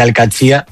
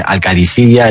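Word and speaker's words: Al-Qadsiah: Bueno, se suele decir Al-Qadsiya, pero también hay gente que dice Al-Qadisiya. Es que es difícil, ¿eh? No es fácil, pero Al-Qadsiya es lo más fácil.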